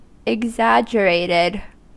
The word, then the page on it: exaggerated: /ɪɡˈzædʒəreɪtɪd/